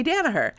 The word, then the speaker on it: Danaher: It's me, your host, and I am joined today by a very special guest, Phoebe Danaher.